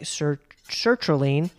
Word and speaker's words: sertraline: Does taking a SSRI like sertraline cause night sweats, specifically from the waist down?